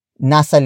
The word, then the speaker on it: nasa: It means around five. Word for word, it's nasa lima. Nasa lima. Nasa lima. The first word is nasa. Nasa. Nasa. Nasa is spelled as N-A-S-A. Previously, we know that nasa means located at, but it also works as an estimation particle.